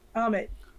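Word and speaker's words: Ammit: Amunet, am I saying that right? – Amit.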